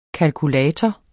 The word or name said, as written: KALKULATOREN